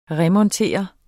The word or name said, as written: remontere